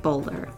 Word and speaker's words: Boulware: His name is spelt like Boulware, but apparently pronounced like Bowler.